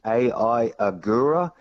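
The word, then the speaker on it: Ai Ogura: Just quickly, I'll just start with the Dutch MotoGP because the first-time winner in that is AI Agura. That is his name. His first name is spelled AI.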